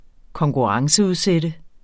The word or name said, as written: konkurrenceudsat